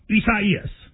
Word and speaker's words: Isaias: A lot of people, prayers to everybody involved, but it is Isaias. – Is that how you say it, though? – You do.